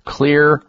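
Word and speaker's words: Xlear: is Clear Rescue Nasal Spray. Write that down. Clear is spelled with an X, X-L-E-A-R.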